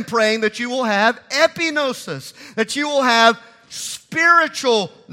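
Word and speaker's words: epignosis: It's the word epinosis. So you take G-N-O-S-I-S and you add three letters to the front of it, E-P-I. That's how we would spell it or say it in English, epinosis.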